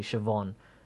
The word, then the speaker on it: Siobhan: That the pronunciation of that, what you said, Siobhan, is actually Siobhan, and I like Siobhan better.